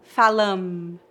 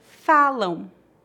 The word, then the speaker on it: falam: Note que não tocamos os lábios para pronunciar o M em final de palavras. Não dizemos falam, e sim falam.